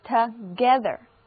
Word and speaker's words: together: 注意字母 o 在这里的读音是短的 a，而字母 e 则发小口的 a，整个单词的重音在第二个音节，所以这个单词就读作 together， together。